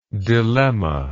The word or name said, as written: dilemmatically